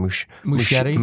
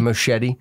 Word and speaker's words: Muschietti: how do you say his last name? – Muschietti. – Muschietti. Mm-hmm.